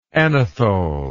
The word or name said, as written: anethole